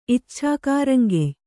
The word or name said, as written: ಇಚ್ಛಾಕಾರಂಗೆಯ್